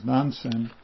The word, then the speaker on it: Nansen: Nanchuan, also known as Nansen, at Nansen's one day, Nansen is the Japanese way of pronouncing it, the residents of the East and West Halls were fighting over a cat, quarreling over a cat.